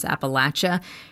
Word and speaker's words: Appalachia: I want to shout out Leah Song, who gave me the perfect mnemonic device for remembering how to pronounce Appalachia. Her grandmother would say, "It's like someone's throwing an apple at ya."